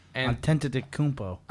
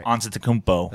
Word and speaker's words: Antetokounmpo: And has, did I say that right? – Hell no. But no. – How do you say it? – I don't know how. – Antetokounmpo. – Antetokounmpo. – Antetokounmpo. – Say it again. You said it right. – Antetokounmpo. – Antetokounmpo. – That's it.